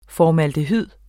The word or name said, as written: formaldehyd